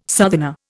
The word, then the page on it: southerner: Indian